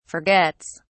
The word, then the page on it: forgets: British English